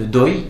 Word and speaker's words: dohi: Ces gens-là, on va les désigner par le terme de doi. Ça s'écrit, en fait, en translittération d'époque, c'est dohi que ça s'écrit, D-O-H-I.